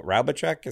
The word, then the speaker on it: Raubitschek: It's Antony and Isabelle Raubitschek.